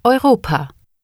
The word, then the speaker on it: Europa: In German, EU is pronounced similar to OY in the English words boy or toy. Listen and repeat. – Europe. – Europa.